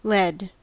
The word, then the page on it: led: American English